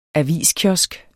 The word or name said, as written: aviskiosk